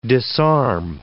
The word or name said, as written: disarm